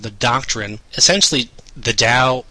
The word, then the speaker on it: Tao: You may be wondering, what is the Dow? Which is spelled T-A-O, but spelled like D-O-W, as in like the Dow Jones or something along those lines.